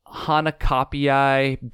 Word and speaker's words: Hanakapiai: And the second one is, I have a hard time with this word, Hanakapiai Beach, I believe is how you say it.